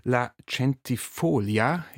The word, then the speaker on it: La Centifolia: Und du hast auch ein eigenes Ensemble, La Centifolia. Ich habe extra nochmal gefragt nach der Betonung. La Centifolia gibt es seit 2019.